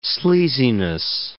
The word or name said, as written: sleaziness